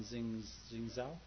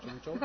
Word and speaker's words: 郑州: Well, you know, I don't feel too bad because I would have, especially this, the name of this town, Jingzhou. How do you say it? – 郑州不是青岛，郑州。 – 郑州。